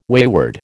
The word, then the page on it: wayward: American English